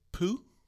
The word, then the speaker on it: Pugh: Florence Pugh? P-U-G-H. How do you say her last name? Florence I'm not sure. – Pugh? I feel like I'm saying poo, but that's not what I meant.